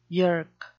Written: yerk

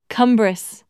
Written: cumbrous

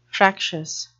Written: fractious

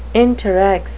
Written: interrex

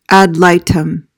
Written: ad litem

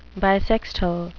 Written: bissextile

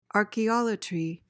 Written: archaeolatry

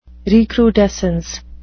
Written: recrudescence

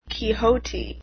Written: quixote

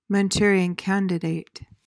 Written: Manchurian candidate

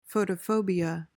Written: photophobia

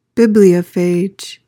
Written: bibliophage

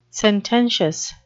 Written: sententious

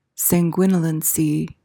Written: sanguinolency

